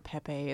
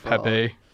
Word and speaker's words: Pepe: Or that's when people the true horror story mispronounce here. The name and are like, it's Pee-Pee the Frog.